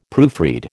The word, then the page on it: proofread: American English